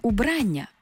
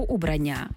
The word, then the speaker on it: убрання: Крім значення чоловічий костюм, можна вживати убрання або убрання, а також вбрання, вбрання. Маю на увазі з такими наголосами, так?